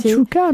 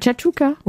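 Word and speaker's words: chakchouka: La fameuse chakouchka, je ne sais jamais le dire, c'est très bon, mais difficile à prononcer. – Chachouka, non? – Oui, oui. – C'est chachouka. – Oui, moi j'ai... – Non, c'est ça, chachouka.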